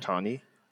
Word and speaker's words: Ohtani: Ohtani.